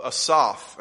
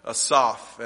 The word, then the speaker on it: Asaph: You'll notice at the top of the psalm, it says a psalm of Asaph, and that's how the Hebrews would have pronounced that. I'll probably fall into the easier pronunciation, but Asaph.